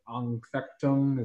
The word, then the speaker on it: Anfechtung: What's that German word? Anfechtung? Is that what it is? Did I say it right? Did I get an A? – Sounds terrific.